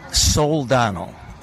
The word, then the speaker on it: Soldano: Soldano. S-O-L-D-A-N-O. – Okay. – Soldano. If you look at it closely, it's spelled S-O-L-D-A-N-O.